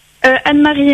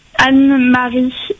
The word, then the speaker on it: Anne-Marie: Anne-Marie Aymar. – Anne-Marie? – Anne-Marie Aymar, A-N-N-E, Marie M-A-R-I-E, Aymar A-Y, M-A-R-D.